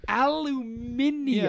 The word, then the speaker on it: aluminium: That pronunciation is so, so dumb. Aluminium. – Yeah, like the, orientate and things like that. That's my question, I guess. I know Canadians use British spellings. – Yeah. – Do they use British pronunciations too? Would a Canadian say aluminium? And I wouldn't know.